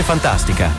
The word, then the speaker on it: Funtastica: Fantastica, si legge Fantastica, ma si scrive con la U.